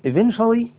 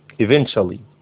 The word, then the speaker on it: eventually: and eventually التي تنطق ايضا برضو شي ديت، احنا قلنا التي عندهم كلها فيها شين كده يعني eventually، حتى هتلاقيها مشبعة تي قليلا يعني، خلاص eventually اخيرا.